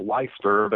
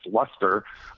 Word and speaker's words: Leicester: I pronounced it Leicester, but it's Leicester.